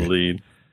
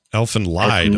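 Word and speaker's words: Elfen Lied: Elfin Lead. – It's a messed up show. – Elfin Lied, it looks like. – Elfin Lied, you mean? – It's pronounced lead, but yes.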